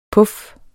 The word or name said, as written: puffen